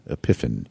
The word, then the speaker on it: Epiphan: They have something, it is called, I think it's pronounced Epiphan, but forgive me if I'm mispronouncing it. It's E-P-I-P-H-A-N.